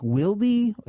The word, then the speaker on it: Wilde: It went to Madison Bumgarner, and to make the presentation, Chevy sent their executive, Rick, and it's pronounced, I think, Wilde. It's spelled W-I-L-D-E, and Rick is R-I-K-K.